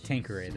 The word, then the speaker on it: Tanqueray: It's pronounced Tanqueray, though.